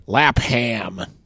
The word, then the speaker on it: Lapham: Dave Lapham, I don't know how to pronounce his last fucking name. – Lapham!